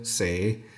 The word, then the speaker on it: sagde: Vi skriver S-A-G-D-E og siger et S og en lang A-lyd. Altså vi siger sage, men vi skriver en hel masse bogstaver, som vi ikke udtaler.